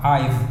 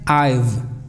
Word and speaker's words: I've: Esta es la contracción de I have, I've. Se pronuncia I've. No es I've con F, no, es con una V aquí. I've, I've, v, v, I've.